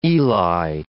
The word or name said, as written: Eli